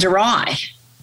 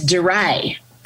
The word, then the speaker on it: Darai: you said it wrong, right? And I was horrified because I was saying, Billy Adadungalung Darai, and it's Darai.